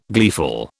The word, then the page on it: gleeful: British English